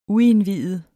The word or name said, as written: uindviet